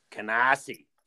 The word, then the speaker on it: Canarsie: So, you know, so Dan, I don't know how much of you, this background you know, but Josh has a studio in Canarsie, Brooklyn. – Canarsie. There you go. You said it right.